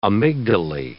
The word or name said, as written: amygdalae